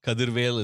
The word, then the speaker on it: Kadirvel: Kadirvel is how I pronounce it. – Say it in the Tamil way. – You want my full name? – Yes, it's we do. – Vel Murugan Kadirvel.